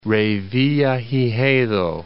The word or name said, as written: Revillagigedo